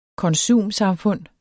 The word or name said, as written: konsumsamfund